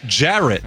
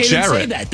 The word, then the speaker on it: Jarrett: The problem is not with the spelling; it's with the pronunciation by people who aren't me, and them arguing on how I pronounce my own name. My name is Jarrett. – Okay, Jarrett. – Then say that. – I do. – No, say it with a qualifier. If you pronounce Jarrett the way I do, it doesn't rhyme with ferret.